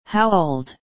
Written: how old

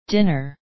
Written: dinner

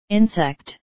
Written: insect

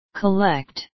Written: collect

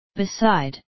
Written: beside

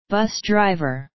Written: bus driver

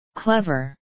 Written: clever

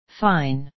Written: fine

